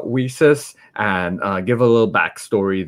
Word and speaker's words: WiCyS: We often go by our acronym, WiCyS, and as WiCyS, which is like pronounced like We Sisters.